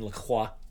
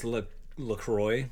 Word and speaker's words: La Croix: Because French pronunciation is La Croix. – La Croix. – The R and the X are gone, so it's La Croix.